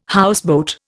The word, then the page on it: houseboat: Indian